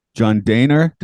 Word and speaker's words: Dehner: John Dehner. Did I just did I say it right? Did I say it like you said it? I don't know. – Yeah, because when I when I seen it up, it's pronounced yeah, day and then her. Yeah.